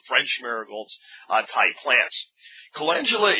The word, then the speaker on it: calendula: Um, I've heard people pronounce it calendula, which I think it's spelled a little close to that, but it's calendula.